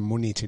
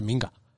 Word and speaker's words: Minga: Que no, es que lo he leído en una página de internet, que no se dice Múnich ni München, que se dice Minga.